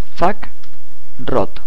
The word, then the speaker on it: fakeroot: Vamos al gestor de paquetes Synaptic, buscar, y ponemos fak root. F-A-K-E-R-O-O-T. Fak root en realidad se pronuncia.